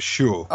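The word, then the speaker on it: Xu: Zhu, X-U, 41, was one of the... – That's actually pronounced Shu.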